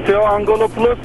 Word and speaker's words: Theo Angelopoulos: Theo Angelopoulos. Telaffuzum yanlış olabilir ya. – Doğru doğru.